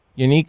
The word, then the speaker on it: Yannick: How do you say his first name? Is it Yannick? – Yannick Ngakoue from Jacksonville. – Yannick Ngakoue. Yeah.